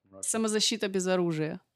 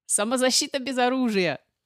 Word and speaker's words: Самозащита без оружия: How do you pronounce that? – Самозащита без оружия.